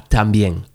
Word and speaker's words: también: and Tambien? Papa Tambien? – Y tu papá también. – Okay, también.